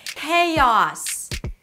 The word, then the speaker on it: chaos: Today we're going to talk about how to pronounce the word chaos. Chaos. There's so much chaos in this world. Okay, we begin with a k sound. The k at the beginning of words is aspirated.